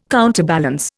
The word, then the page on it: counterbalance: Indian